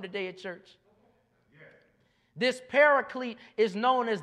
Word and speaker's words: Paraclete: Somebody say Paraclete. If you're trying to figure out how to spell it, it's P-A-R-A-C-L-E-T-E. That's Paraclete. Once again, that's P-A-R-A-C-L-E-T-E, Paraclete.